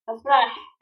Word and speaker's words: Afrah: Yes, Afrah.